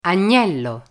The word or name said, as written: agnello